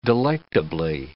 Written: delectably

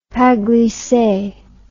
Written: pas glisse

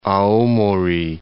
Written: Aomori

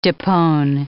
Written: depone

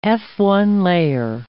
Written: Fa1a layer

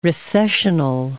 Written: recessional

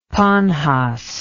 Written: pannhas

